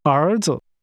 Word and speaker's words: 儿子: 儿子. – Sons is pronounced with rising and falling rising tones. Say, sons.